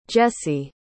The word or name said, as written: Jesse